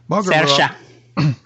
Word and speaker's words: Saoirse: Because she's up against, and I'm going to mispronounce it, Saoirse Ronan for Lady Bird. – Saoirse. – Pardon? – Saoirse Ronan. – Is that how you pronounce it? – Yeah, okay.